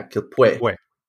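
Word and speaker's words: Quilpué: There's an accent on the E. – Accent on the E, Pue. Yeah, Quilpue.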